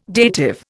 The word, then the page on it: dative: Indian